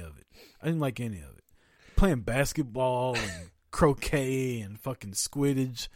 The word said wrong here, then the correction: Quidditch: Like, did I not say it right? Quidditch? That's it. Quidditch.